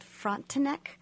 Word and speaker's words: Frontenac: The story is set in a fictional town in Kansas called Manifest, based on a real town called Frontenac. I hope I'm saying that right, Kansas. – Yeah. Uh-huh. I think you're pretty good. – It's correct.